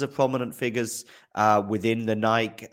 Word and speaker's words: Nike: Yeah, so and I believe that Nike is the Greek pronunciation. – Yeah, it does have a bit of a European heritage in that sense. – Yeah, but I think Nike is the correct pronunciation, right? – That's correct.